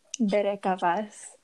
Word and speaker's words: Bereka Vas: I pronounce my Instagram as Bereka Vas, which basically it's the I got it out of a kid from church who he couldn't ever pronounce my name and he would just pronounce it as Bereka.